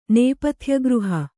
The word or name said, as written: ನೇಪಥ್ಯಗೃಹ